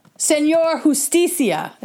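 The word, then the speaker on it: Señor Justicia: Oh, Senor Justicia is how you pronounce it.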